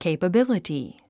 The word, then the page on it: capability: kèipəbíləti; ケイパビリティ